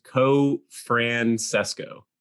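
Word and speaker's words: Cofrancesco: Okay, so for anyone wondering, it's the same as it's spelled. I know it's long and confusing. I can hardly spell it, but it's just Co-Francesco.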